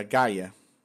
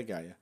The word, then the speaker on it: Gia: Do Game of Thrones, é, que é a, é a Gaia. A pronúncia correta, acho que eles usam no, no, no negócio. Se, se escreve Gia, mas é Gaia.